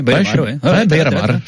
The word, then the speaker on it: Beira-Mar: Tu me dis si je me trompe sur les prononciations, mais c'est à Chaves, c'est une montée de 3e en 2e division à Beira-Mar, c'est ça? On est, je suis bien. – Club d'Aveiro, ouais. – Beira-Mar, c'est bien.